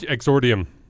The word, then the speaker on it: Exordium: Excordium. Wait, let's sound this out. It's a multi-syllable word. I would divide it up here. Here. How the fuck do you say it? – Mirror's Edge Exordium. – Oh, I did say Exordium.